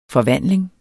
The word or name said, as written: FORVANDLINGER